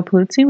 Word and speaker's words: Pulizzi: I'm joined today by Joe Pulizzi with Content Marketing Institute.